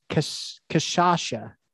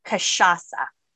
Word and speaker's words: cachaça: You told me how to say it. It's cachaça. I think I said it the same way that you corrected, but how do you say it again? – It's cachaça. Cachaça.